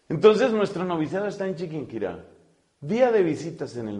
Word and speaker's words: Chiquinquirá: Noviciado de los padres dominicos en Colombia, ciudad de Chiquinquirá. Así se llama, Chiquinquirá. Hay que aprender a pronunciarla porque ahora resulta que algunas de mis amigas dominicas no saben decir Chiquinquirá, hay que saberlo pronunciar.